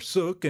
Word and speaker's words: sook: Two words that are still in common use in the rural Ozarks are sook and stob. Sook most likely represents a Scottish pronunciation of the word suck, as in a suckling calf.